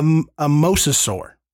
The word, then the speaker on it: mosasaur: But if you have, or if you've at least watched the trailer, you have seen at least, a rendering of a mesosaur. – Mosasaur. – You said it right.